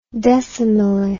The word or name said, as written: decimilli